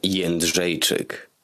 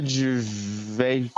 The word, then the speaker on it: Jędrzejczyk: Here is the proper pronunciation of our fighter's name. It's Joanna Jędrzejczyk. – Oh, that's a dark-looking bitch. I bet she could be some ass. – Joanna Jędrzejczyk.